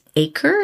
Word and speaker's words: Aker: It is Acre, is how I believe it's pronounced, like acres of land, maybe. Mm-hmm. Mm-hmm. It's spelled A-K-E-R, and this is the English Rosé aperitif, and it's made with all English botanicals, with all English plants from the English countryside.